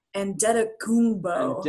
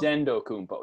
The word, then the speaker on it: Antetokounmpo: It's like the way it's pronounced, because I look at it every time. When the Warriors play the Bucks, you get the opposing team's game notes as well. And there's the pronouncer. And it's there's like a D. It's like Antetokounmpo.